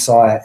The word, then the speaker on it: Sire: So for all your listeners, it's called The Universe Next Door by James Sire. Excellent book. – How do you spell that surname? Last name? – S-I-R-E. S-I-R-E.